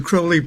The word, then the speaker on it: Crowley: How's the correct way to pronounce Aleister Crowley or Aleister Crowley? – Well, the way Aleister Crowley pronounced it was Crowley, and he said it rhymes with holy.